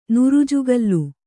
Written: ನುರುಜುಗಲ್ಲು

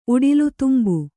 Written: ಉಡಿಲುತುಂಬು

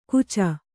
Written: ಕೂಚ

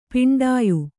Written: ಪಿಂಡಾಯು

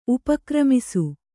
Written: ಉಪಕ್ರಮಿಸು

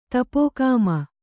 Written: ತಪೋಕಾಮ

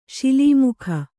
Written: ಶಿಲೀಮುಖ

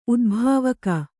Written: ಉದ್ಭಾವಕ

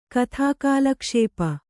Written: ಕಥಾಕಾಲಕ್ಷೇಪ

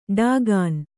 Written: ಢಾಕಿನಿ

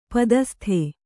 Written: ಪದಸ್ತ

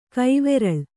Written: ಕೈವುರುಳ್

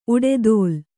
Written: ಉಡೆದೋಲ್

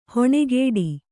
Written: ಹಣೆಕಟ್ಟು

